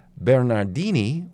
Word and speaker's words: Bernardini: No, she was an exotic dancer, Micheline Bernardini, which rhymes with bikini and martini.